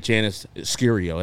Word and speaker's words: Janice Scurio: In the middle is the great Janice Scurio.